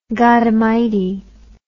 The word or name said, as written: godamighty